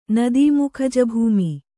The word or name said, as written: ನದೀಮುಖಜಭೂಮಿ